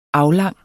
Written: aflang

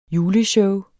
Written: juleshow

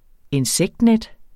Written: INSEKTNET

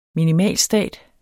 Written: minimalstat

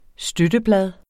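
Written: støtteblad

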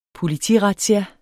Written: politirazzia

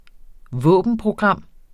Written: våbenprogram

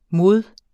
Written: mod

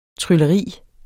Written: trylleri

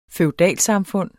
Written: feudalsamfund

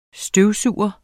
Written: støvsuger

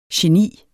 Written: geni

